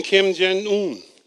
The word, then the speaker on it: Kim Jong-un: Even Kim Jong-un. And I don't mean to mispronounce his name. I'm not mocking him. Did I pronounce it right, first of all? I did? Okay, good.